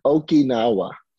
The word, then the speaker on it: Okinawa: After Okinawa, I'm not even saying that right, am I? Okinawa? – Yeah, okay. Okinawa.